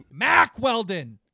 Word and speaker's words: Mack Weldon: Mark Weldon. Mark Weldon. Mack Weldon. – You what? said it wrong.